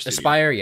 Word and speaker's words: Aspyr: Aspyr, yeah, Aspyr too.